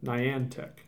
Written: Niantic

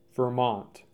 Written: Vermont

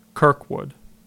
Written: Kirkwood

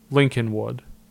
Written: Lincolnwood

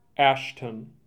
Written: Ashton

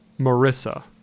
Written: Marrisa

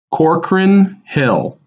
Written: Corcoran Hill

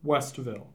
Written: Westville

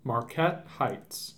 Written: Marquette Heights

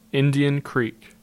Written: Indian Creek

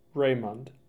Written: Raymond

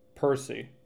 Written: Percy

